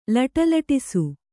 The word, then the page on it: ಲಟಲಟಿಸು: laṭalaṭisu